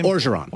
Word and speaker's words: Orgeron: Orgeron.